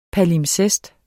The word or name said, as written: palimpsest